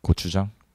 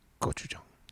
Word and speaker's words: gochujang: How do you say it? – Gochujang. – Gochujang. – Gochujang. – gochujang. – It's just softer. We do the G's kind of soft. – Go. – Yeah. – Gochujang.